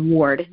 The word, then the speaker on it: Ward: It's Mary and then Isla, I-L-A, it's two words, it's a double name, and then my last name is Ward, W-A-R-D.